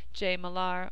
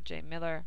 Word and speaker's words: Millar: Thanks again to the St. The Rosedale Library, Jay Millar, or Jay Miller, which is really how it's pronounced, but because it's spelled funny, people say Jay Millar, and his speakeasy, and everybody who attended.